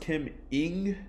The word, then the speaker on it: Ng: I believe her name is pronounced Kim Ng. – Yes. – That's what I looked up. – Yep. – So Kim Ng, so first female GM in all sports.